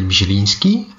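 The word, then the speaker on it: Mizieliński: En ze kunnen dat eigenlijk het beste zelf uitspreken. Hello, my name is Daniel Mijelinski and my wife is Alexandra Mijelinska.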